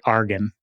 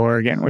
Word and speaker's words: Oregon: I should say, so all of my Philadelphia brethren are probably shaking their fists at the air right now because back in Philadelphia, they pronounce it Argon instead of Oregon, which is a really funny thing.